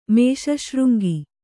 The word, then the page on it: ಮೇಷಶೃಂಗಿ: Kannada